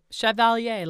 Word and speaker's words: Chevalier: In April 2016, the French government appointed Vinterberg a Chevalier. Did I say that right? – I think so. – Of the Order des Arts et des Lettres. – Yes, that's right. Chevalier, like Maurice Chevalier.